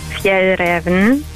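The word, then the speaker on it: Fjällräven: Und es wird richtig ausgesprochen, Fjell Raven. – Fjell Raven. – Als S werden im Deutschen als S ausgesprochen.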